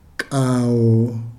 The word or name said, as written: kao